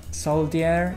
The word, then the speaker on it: soldier: Soldier, no. It sounds like a J, so we say soldier.